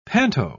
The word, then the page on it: panto: British English